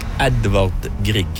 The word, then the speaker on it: Edvard Grieg: Edvard Grieg.